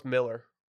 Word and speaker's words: Millar: Pronounced Miller.